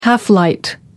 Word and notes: half light: American English

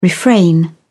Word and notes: refrain: British English

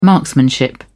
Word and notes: marksmanship: British English

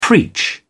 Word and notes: preach: British English

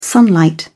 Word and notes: sunlight: British English

